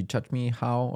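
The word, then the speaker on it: Csikszentmihalyi: Because it's a Chi Chut Me How or something like that. It's like, that's literally how it's pronounced.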